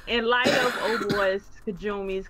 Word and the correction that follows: Kojima: Kajamis, Whatever. Kojima. It's called Jima.